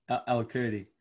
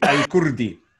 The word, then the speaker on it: Elkurdi: Elkurdi. – Elkurdi. – No, you got to say it properly in Arabic. Elkurdi.